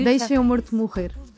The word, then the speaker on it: Deixa Meu Morto Morrer: It's in Portuguese, it's very hard to pronounce Portuguese, but it's almost perfect. – No, you Deixa Meu Morto Morrer.